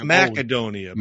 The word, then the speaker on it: Macedonia: So Macedonia, if I'm pronouncing it right. – That's I think it's Macedonia. – Macedonia. – Yeah. – Like macadamia. – Yes, exactly.